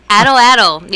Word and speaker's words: atlatl: What is it? Atlatl program? – Atlatl. Yeah, okay. No one ever knows how to pronounce that.